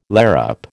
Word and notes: larrup: American English

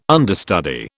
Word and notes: understudy: British English

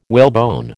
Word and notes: whalebone: American English

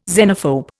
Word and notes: xenophobe: Indian

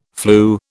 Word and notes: flu: British English

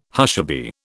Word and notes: hushaby: British English

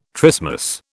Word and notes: trismus: British English

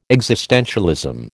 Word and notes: existentialism: American English

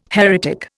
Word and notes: heretic: Indian

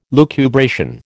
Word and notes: lucubration: American English

